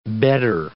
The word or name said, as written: bedder